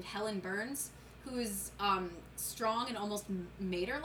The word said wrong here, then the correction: martyr: I pronounced mater wrong. what do you mean you pronounced mater wrong? I thought, didn't. – Is that how you pronounce Is it martyr or mater? Martyr. – Uh, m A Y T. – Oh yeah, martyr.